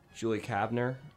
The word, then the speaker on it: Julie Kavner: I couldn't find it, but I was looking up Julie Kavner, who does Marge.